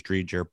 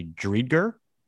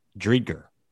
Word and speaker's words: Dreger: I thought it was Dreger, but it's apparently Dreger. That's that. And I listened to the game, and I trust this guy, Steve Goldstein. Dreger. It's a hard G.